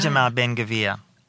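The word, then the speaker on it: Itamar Ben Gvir: That Itmar Ben, how do you pronounce his um, name? Itamar Ben Gavir.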